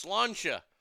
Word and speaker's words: Sláinte: For all you fucking English, it's pronounced Sláinte.